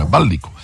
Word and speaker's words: Ballicu: Finalmente abbiamo scoperto come si pronuncia il cognome del mitologico Marco Ballicu, con l'accento sulla I. Ballicu.